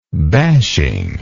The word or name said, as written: bashing